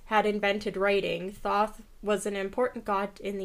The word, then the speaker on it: Thoth: The ancient Egyptians believed that the god, some pronunciations differ. People say it as either Toth or Thoth. – I heard at least one of those. I've heard Thoth, I think. Yeah. Nah, that's hard to say. – Yeah, it is. When I was looking up pronunciation, the source I had was somebody that said that the safest way is pronounced Thoth.